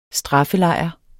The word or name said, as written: straffelejr